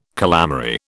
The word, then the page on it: calamary: British English